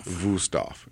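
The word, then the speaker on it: Wüsthof: Wusthof. Yeah. Looks like Wusthof. Pronounce Wusthof.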